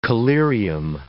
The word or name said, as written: collyrium